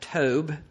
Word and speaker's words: Tob: He got away from them, dwelt in the land of Tob, T-O-B, but pronounced T-O-B-E, Tob, a city north of Gilead at the border of Syria.